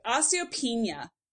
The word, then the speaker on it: osteopenia: So osteopenia.